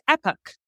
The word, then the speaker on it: Epoch: We were like, Brendan, how would you pronounce E-P-O-C-H? And he's, in America, it's Epoch. In England, it's Epoch.